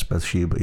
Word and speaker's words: Bathsheba: Bathsheba. Am I saying that correctly? Yes, Bathsheba. Yeah.